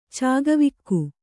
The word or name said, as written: ಚಕ್ಕವಕ್ಕಿ